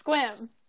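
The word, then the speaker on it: Sequim: One syllable, although it's pronounced quite differently than the way it's spelled. – Yes, exactly. – Oh, okay. It's Squim.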